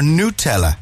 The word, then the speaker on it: Nutella: Nutella, the ultimate comfort food, or Nutella, as it's supposed to be. Yeah, that's the actual pronunciation of Nutella.